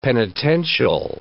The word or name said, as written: penitential